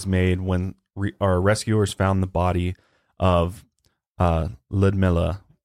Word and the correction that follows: Lyudmila: That's probably not how you say her name, but Ludmila. – Ludmila.